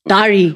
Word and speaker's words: Dary: You know, and then so Joseph Dary comes from my nickname, which is Dary. – Why is your nickname Dary? Is it Dary? – Is it Diary or Dary? – Why? Just Dary.